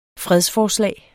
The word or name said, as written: fredsforslag